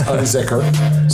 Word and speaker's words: Unzinger: Because I always feel just like it's spelled. It's not as frightening as it looks at first. It's Unzinger. So it's okay. Spelled and pronounced the same.